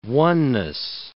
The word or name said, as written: oneness